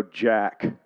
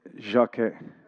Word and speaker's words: Jacques: This is a guy named Jacques Derrida. Look at that French pronunciation there. We could go Jack, as Americans would do. Jacques. It's actually a little bit of an A at the end of it.